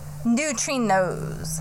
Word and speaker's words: neutrinos: About Antarctica neutrinons unlocked. – Neutrinos. – But, no, I keep saying it wrong.